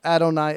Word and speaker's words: Adonai: As the rabbi was saying and explaining, he says that the Jewish people, when they read it in Hebrew, they pronounce Adonai Eloheinu,